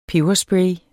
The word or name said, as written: peberspray